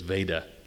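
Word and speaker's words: Vajda: Father Vada, it's V-A-J-D-A, but I understand the authoritative pronunciation is Vada, like Darth.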